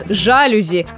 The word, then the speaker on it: жалюзи: Казалось бы, все мы миллион раз слышали, что французы ставят ударение на последний слог. Так почему же у нас до сих пор на окнах в основном висят жалюзи, а не жалюзи? Запоминаем все вместе и окончательно. Жалюзи – ударение на последний слог и никак иначе.